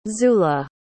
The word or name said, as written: zula